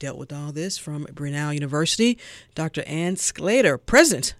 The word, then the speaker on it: Brunel: Brunel, because everybody I talk to pronounces it differently. – Yes, it is Brunel.